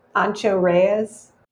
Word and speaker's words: Ancho Reyes: The Paper Plane is another cocktail made with Old Forester 100 proof bourbon, Aperol, Ancho Reyes chile liqueur. Did I say that right? Ancho Reyes? – Yeah, Ancho Reyes.